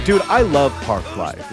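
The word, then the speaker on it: Parklife: Like say it like pork. – Park Life.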